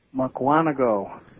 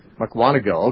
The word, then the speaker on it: Mukwonago: I don't know if I pronounced that correctly, but it's somewhere in Wisconsin. And welcome to The Narrow Path. How do you pronounce the name of your place? – Mukwonago. – Mukwonago, okay.